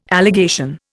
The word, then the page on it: allegation: Indian